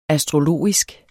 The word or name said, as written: astrologisk